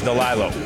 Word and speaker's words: Delalo: It's spelled Delalo. – Delalo. – It's pronounced Delilo. – Delilo. – Or I've always pronounced it Delilo and nobody's ever corrected me.